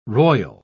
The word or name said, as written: roil